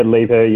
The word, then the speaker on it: Liebherr: So you kind of worked in a variety of different sort of sales roles at Transurban, Gruenveld, you know, how do I pronounce Liebherr, is that correct? – Yeah, Liebherr, yeah.